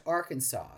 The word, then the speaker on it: Arkansas: should be spelled Arkansas, but pronounced Arkansas.